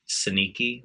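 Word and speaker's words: synechiae: Sneaky. When I was on my obs guide notation, that's how the obstetricians pronounced it. Granted, I went to school in Australia, but like they said sneaky.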